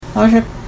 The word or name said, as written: aazha